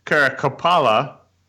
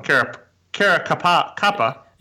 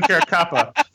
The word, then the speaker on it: Caracappa: He teamed up with famous mob lawyer Bruce Cutler to represent Louis Ippolito and Stefan Caracappa. I said that wrong. Caracappa. – It hurts my ears when you do that. – Stefan Caracappa.